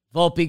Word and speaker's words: Volpe: And, man, look what Gleyber found, because he throws the glove out there, little ole, gets it to Volpe. Pronounce the E. Volpe gets to throw to first.